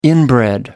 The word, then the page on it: inbred: American English